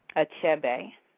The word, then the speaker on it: Achebe: And how do you pronounce his name? – Last name is Achebe.